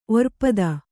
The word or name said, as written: ಒರ್ಪದ